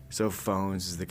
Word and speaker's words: Fauxnz: The four-piece band spells its name F-A-U-X-N-Z. This week I mentioned to band members that the spelling could create some confusion about its pronunciation. – Yeah, that's true. – Yeah, it's phones.